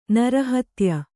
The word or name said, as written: ನರಹತ್ಯೆ